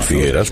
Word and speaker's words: Figueres: Figueres, puedes decir Figueras porque es una E abierta. Y cuando se pronuncia en catalán, se pronuncia igual que en castellano.